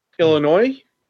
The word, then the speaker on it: Illinois: Illinois. – Okay. – I didn't pronounce the S.